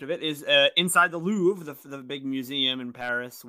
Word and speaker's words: Louvre: Oh, Can't Help Falling in Louvre, like the museum.